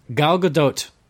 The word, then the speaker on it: Gal Gadot: and she taught us how to say her name properly, which, it is... – It's Gal Gadot. – Gadot. – Yes. – It's a tough one. But the T is not silent, so I have to apologize to Gal Gadot for the past year where I've been saying Gal Gadot.